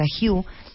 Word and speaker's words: HU: una palabra que es h HU, pero que se pronuncia Hugh, para, para llamar al poder divino.